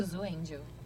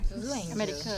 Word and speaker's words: Zuzu Angel: que é a Zuzu Angel, eu falei certo? – Zuzu Angel. Angel, é? Zuzu Angel.